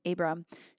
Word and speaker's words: Abram: And this is um, the story is called Son of Laughter, and it's talking about Abram.